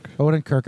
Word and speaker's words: Odenkirk: Odenkirk on that schizophrenia show that he was on, where it was kind of half cartoonish.